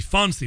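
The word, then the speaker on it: Phonsie: Fonsy. Well, it's spelled P-H-O-N-S-I-E. P-H-O-N-S-I-E. It isn't Fonsy, as in, hey, Bishop Fonsy Cullinan.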